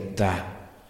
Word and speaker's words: Ptah: Habla del dios Ptah, P-T-A-H. Yo supongo que se debe pronunciar más o menos así, Ptah.